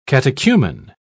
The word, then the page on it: catechumen: British English